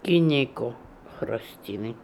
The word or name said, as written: Gynekokhrostiny